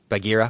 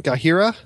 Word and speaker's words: Bagheera: Is that pronounced correctly? Bagheera? – Yep.